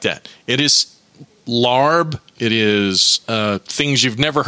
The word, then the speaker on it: larb: But L-A-A-R-B. It's pronounced lob, but there's an R in there in the English translation.